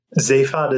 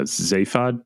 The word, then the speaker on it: Zaphod: Then there's, is it Zaphod? Zaphod? – Zaphod is how they pronounce it in the British series, but even from what I've seen from that, there's sometimes they change up how they're pronouncing it. – Gotcha. So yeah, Zaphod, you said?